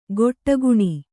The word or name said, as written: ಗೊಟ್ಟಗುಣಿ